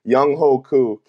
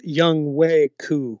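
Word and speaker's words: Younghoe Koo: Young Hoku, kicker. They're all the free agents they got. – It's pronounced Young Way Ku. – My bad. Young, it's not Young Ho.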